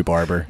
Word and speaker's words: Barber: Is that how you pronounce it? Baba? – No, it's just Joey Barber. – Like all these years, I've been saying it Barber. – It's just Joey Barber.